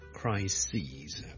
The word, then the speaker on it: crises: 上一集讲嘅最常见嘅例子就 crisis 啦，crisis 一个危机啦，a big crisis 啦，但系 number of crises， OK，留意个发音啊，串字当然系唔同啦，个发音系唔同嘅，crisis 同 crises。